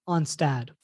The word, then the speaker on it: Onstad: Did you just call him Pat Onstead? – Yes. Did I say it wrong? Is it Onstead? – Onstad. – Onstad. It's an A.